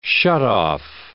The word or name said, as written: shutoff